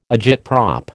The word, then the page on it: agitprop: American English